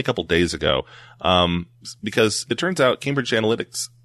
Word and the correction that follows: Analytica: or Analytica, I've been saying that wrong the whole time, Analytica, lied about deleting the information.